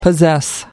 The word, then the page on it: possess: American English